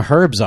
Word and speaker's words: herb: You know, it's like crazy they actually pronounce the H. – It's an Eddie Izzard joke. Yeah. And we say herb because there's an H in it.